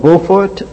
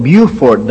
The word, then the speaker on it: Beaufort: There's a little town in South Carolina called Beaufort. They call it Beaufort down there, but it's Beaufort. That's the French pronunciation.